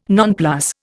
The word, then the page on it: nonplus: Indian